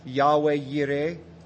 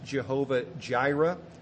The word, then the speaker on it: Yahweh Yireh: It's pronounced Yahweh Yireh. Oftentimes it's pronounced Jehovah Jireh.